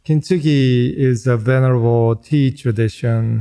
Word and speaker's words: kintsugi: You discuss in the book this ancient Japanese technique of kintsugi. – Yeah. – Is that how I pronounce it correctly? – Yes, kintsugi. Nice.